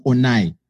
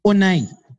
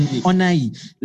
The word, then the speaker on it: Onai: The pronunciation of my name is not Onai. It's Onai. Like, it's an Onai.